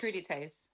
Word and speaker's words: crudités: crudités.